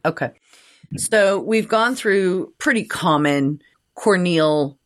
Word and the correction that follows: corneal: sorry, I wrong emphasis on the wrong syllable. – Yep. – Corneal injuries.